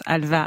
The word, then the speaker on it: halva: En Turquie, en Arménie ou en Israël, où on prononce halva avec un V.